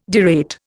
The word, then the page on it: derate: Indian